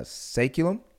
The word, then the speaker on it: Saeculum: called uh, Saeculum. Correct. Is that how you is that how that's you pronounce yeah, it? Yeah, actually, you're okay. probably the first one to actually say it right the first time.